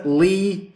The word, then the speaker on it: Leigh: Sir Lee Teabing, pronounced L-E-I-G-H, Teabing.